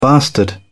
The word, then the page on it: bastard: British English